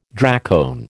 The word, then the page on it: dracone: American English